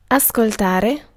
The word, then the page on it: listen: /ˈlɪs.ən/; /ˈlɪs.n̩/